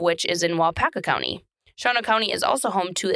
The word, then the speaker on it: Shawano: Shawano County is spelt Shawano, S-H-A-W-A-N-O, but it's pronounced Shawano.